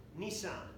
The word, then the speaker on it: Nisan: month Nisan, not the car, but a different pronunciation slightly.